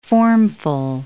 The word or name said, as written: formful